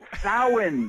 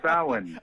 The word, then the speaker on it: Samhain: Samhain. I have to correct you. I cannot help it. My Irish ancestors are screaming at me. Samhain, my friend. Samhain.